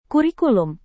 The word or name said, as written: kurikulum